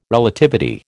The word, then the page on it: Relativity: American English